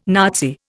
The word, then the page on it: Nazi: Indian